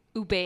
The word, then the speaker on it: ube: mispronounce simple words, right? Like ube. Ube. – Oh. No, they say ubi. Ubi.